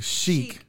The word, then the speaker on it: Chic: It's the Country Chic, not Country Chick.